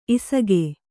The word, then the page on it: ಇಸುಗೆ: isuge